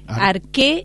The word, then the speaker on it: Arche: Arqué es la pronunciación correcta, pero se escribe Arche.